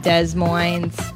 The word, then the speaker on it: Des Moines: And then October 16th through 19th, the Des Moines Funny Bone in Des Moines, Iowa.